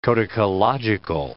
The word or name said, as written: codicological